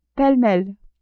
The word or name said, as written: pele-mele